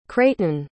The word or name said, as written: creighton